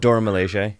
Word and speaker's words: Dormammu: And then it goes into, like, it's just showing a lot of the Dormammu. I don't want to pronounce it wrong. – Dormammu, yeah.